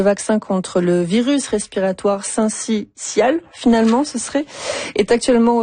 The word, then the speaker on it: syncytial: Non, selon le dictionnaire Usito, on prononce le syncytial. – Voilà. Syncytial, voilà.